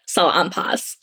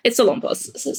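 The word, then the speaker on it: Salonpas: There's not a lot of, probably the biggest Filipino brand that you've ever heard of is, they pronounce it, Salampas. So that heat patch, that's a Filipino brand. But that's like the most recognizable. It's Salampas. So,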